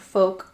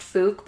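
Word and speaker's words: Fouke: And I thought it was almost pronounced Fook, but I had to pull up the Google pronunciation just to make sure I wasn't fucking it up. – Yeah, so it is pronounced Folk. – Yeah, rhymes with poke.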